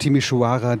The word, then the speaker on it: Timișoara: Wir haben gelernt, Temeswar ist die deutschsprachige Aussprache und Timișoara die rumänische.